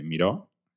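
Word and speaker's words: Mireau: Y tras el fracaso de esa operación, el general Miró, que se pronuncia igual que el artista, pero se escribe distinto,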